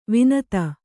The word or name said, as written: ವನಧಿ